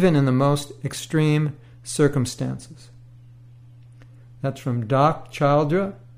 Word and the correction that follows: Childre: and Howard Martin. Doc Childre. It's spelled C-H-I-L-D-R-E.